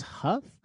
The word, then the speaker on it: Hough: How do you say that name? Hough? – I thought it was Huff. – Huff? That makes more sense.